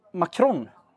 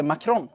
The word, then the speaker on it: Macron: Jag har ju alltid sagt Macron. – Mm. – Uttalat det Macron. – Ja. – Och så, jag tror att alla andra har gjort det också. – Mm. – Tills dess att det har hänt en slags skiftning i hans status i svenskarnas ögon, tror jag. Det vill säga, när en fransk president kommer till Sverige... – Mm. – Då skärper folk till sig och börjar uttala namnet på franska.